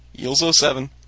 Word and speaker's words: EELZ07: I have no clue how to pronounce that one. EELZ07. Is that Eels? – Eels 07.